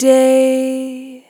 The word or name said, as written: Long Day